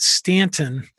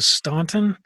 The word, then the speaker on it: Staunton: to Staunton, so they could tell who was from the north. – That's fascinating. – So it's Staunton if you want to blend in, but it's really Staunton.